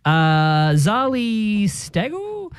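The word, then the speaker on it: Zali Steggall: Zali Stagel? – Nailed it.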